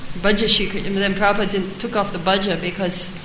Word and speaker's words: Prabhupada: I think there is, isn't there, there's no long A there? – Prabhupada. – Oh, Prabhupada. – There's a long A. – Oh, at the end, at the end. Oh, yeah, Prabhupada. That was short.